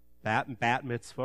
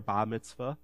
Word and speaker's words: bat mitzvah: Bar mitzvah or bat mitzvah or bat mitzvah. Depending if you pronounce the T or not, but B-A-T mitzvah for women.